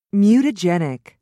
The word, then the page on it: mutagenic: British English